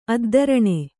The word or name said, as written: ಅದ್ದರಣೆ